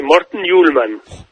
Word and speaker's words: Morten Hjulmand: Bueno, lo dijiste bien, Morten Hjulmand.